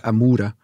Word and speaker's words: Amoura: Daar, zat ik al een hele week, dus ik wou weten nog dat je het moet uitspreken. Het is blijkbaar Amoura.